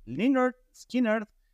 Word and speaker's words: Lynyrd Skynyrd: Y pues primero de los clásicos, no podría ser de otra manera, Lynyrd Skynyrd, quien no sabrían en algún momento cómo se pronunciaba, pues es así, Lynyrd Skynyrd, con su clásico Sweet Home Alabama.